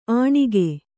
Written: ಅಣಿಗೆ

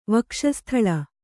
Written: ವಕ್ಷಸ್ಥಲ

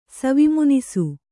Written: ಸವಿಮುನಿಸು

